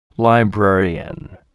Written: librarian

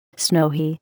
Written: snowy

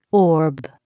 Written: orb

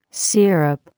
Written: syrup

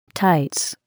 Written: tights

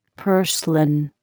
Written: purslane

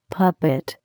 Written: puppet

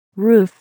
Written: roof

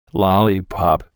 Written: lollipop

